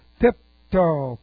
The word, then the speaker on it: pipto: This is the aorist active participle of pipto. P-I-P-T-O. P-I-P-T long O. Pipto.